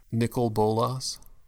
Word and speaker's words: Nicol Bolas: Nicol Bolas.